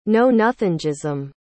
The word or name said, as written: knownothingism